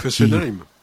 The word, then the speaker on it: Fessenheim: C'est elle qui a fermé Fessenheim, la centrale nucléaire. – Fessenheim.